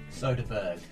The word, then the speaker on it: Soderbergh: Soderbergh.